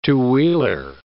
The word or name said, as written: two-wheeler